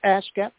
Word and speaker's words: ASCAP: And well, yeah, that's you said it right. It's ASCAP first, and it's BMI, and it's also CSAC.